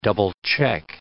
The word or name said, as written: double-check